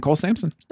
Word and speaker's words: Cole Sampson: And Cole Sampson.